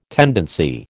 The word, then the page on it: tendency: American English